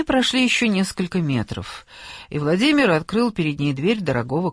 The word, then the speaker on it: Владимир: Владимир с ударением на мир».